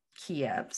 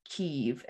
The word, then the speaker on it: Kyiv: Yeah, it's come out that the Ukrainian capital has been the Russian pronunciation for a really long time. So I guess if the more Ukrainian way to say it is Kyiv, and it's spelled K-Y-I-V instead of K-I-E-V, like chicken.